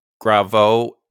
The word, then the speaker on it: Graveaux: Gravo is spelled G-R-A-V-E-A-U-X. So French.